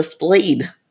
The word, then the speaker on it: Splayd: It's The Splayed, and it's spelled S-P-L-A-Y-D.